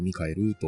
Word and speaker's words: 大天使: 大天使って書いてミカエルなんですね。 – そうですね。漢字で笑う大天使と書いて笑うミカエルと読むやつですね。